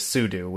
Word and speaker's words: sudo: And by the way, most people that I know say sudo, but officially the pronunciation is sudo, which is why I try to say it that way.